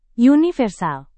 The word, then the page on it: universal: Indonesian